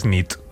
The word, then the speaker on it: nit: Y noche es nit. – Muy bien. Buena nit. Buena nit, es verdad. – Buena nit.